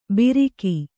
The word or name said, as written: ಬಿರಿಕಿ